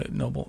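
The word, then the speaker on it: Noebel: Noble. – Just go with it. – His name is spelled weird. – And it is. It's N-O-E-B-E-L.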